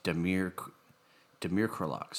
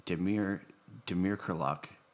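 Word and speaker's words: Damir Kreilach: And actually, the Whitecaps show you how to pronounce it when you look at the pronunciation is Demir Krylak. So it is Demir Krylak, acquired by the Whitecaps.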